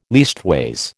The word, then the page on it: leastways: American English